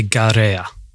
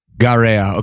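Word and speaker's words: Gaerea: So it's actually Garea. – Garea.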